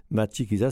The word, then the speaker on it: Matshikiza: Matshikiza s'écrit M-A-T-S-H-I-K-I-Z-A.